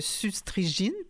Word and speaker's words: suzetrigine: c'est S-U-Z-E-T-R-I-G-I-N-E. – Oui, il n'y a pas d'accent, mais j'ai justement cherché la prononciation et on le prononce comme s'il y avait un accent aigu.